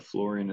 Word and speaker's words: Florian: And since I know that Florian is not a very common name out here, I'm going to spell it briefly.